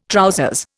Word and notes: trousers: Indian

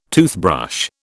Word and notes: toothbrush: British English

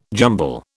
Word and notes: jumble: British English

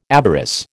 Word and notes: avarice: American English

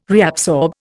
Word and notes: reabsorb: Indian

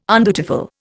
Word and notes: undutiful: Indian

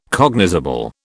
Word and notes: cognizable: British English

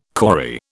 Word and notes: kauri: British English

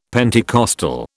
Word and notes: Pentecostal: British English

